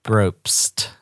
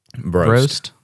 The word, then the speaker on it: Probst: Hunter Probst. – Probst. – It's like you just say bro, and then like if you were trying to get somebody's attention, you just go psst.